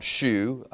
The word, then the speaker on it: shew: Well, show, when you look it up, S-H-E-W, some people pronounce shoe. I think that's the way Ed Sullivan used to pronounce, "We're going to have a really good shoe." For any of y'all that ever watched those. But if you look up the word shoe, it can be pronounced shoe or pronounced show.